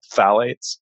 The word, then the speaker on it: phthalates: But the other problem with plastics is they also have something called phthalates, and that's spelled P-H-T-H-A-L-A-T-E-S, phthalates.